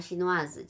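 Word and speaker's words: chinoise: 就是加一个 e 在后面的，就那个 s 发音的话，She Noirs，就中国人是 She Noir，嗯，就是 She Noirs 就是中国。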